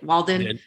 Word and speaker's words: Walden: And immediately they flew us to San Francisco to write with Michael Narada Waldman. I think I said that right. Walden. – Yeah. – Michael Narada Waldman. Yeah. – Walden.